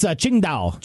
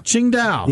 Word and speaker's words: Qingdao: When you talk about beer from China, most people probably think of, I'm going to say this wrong, I know it. – It's okay. – Qingdao? – It's Qingdao. – Qingdao. – Exactly.